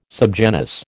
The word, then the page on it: subgenus: American English